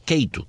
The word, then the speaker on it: Cato: Ian Vázquez es el director del Centro para la Libertad y la Prosperidad Mundial de la Fundación Cato, Cato, como se debe pronunciar, pero se escribe C-A-T-O, Cato.